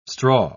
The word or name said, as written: straw